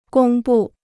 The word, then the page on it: 公布: gōng bù